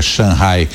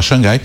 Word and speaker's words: Shanghai: Ele pronuncia Shanghai como é a pronúncia lá na China, que normalmente a gente fala Xangai, e o Luca está por lá acompanhando de perto essas informações.